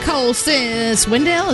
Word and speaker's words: Cole Swindell: And Cole Swindell.